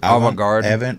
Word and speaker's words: Avant-garde: Avant-garde.